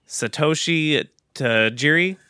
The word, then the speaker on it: Satoshi Tajiri: So the initial concept for Pokemon was created by, and I'm going to butcher his name, Satoshi Tajiri.